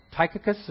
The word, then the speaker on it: Tychicus: There's another reference to that in Colossians 4:7 to, is that Tychicus? Is that how it's pronounced? Tychicus? That's how I'd pronounce that.